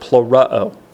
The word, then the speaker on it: plerao: And as Wayne pointed out, that word fulfilled in verse 4 is from the Greek word plerao. Plerao. And always I say emphasis on the uh-oh because of how it's translated in Matthew 5:17.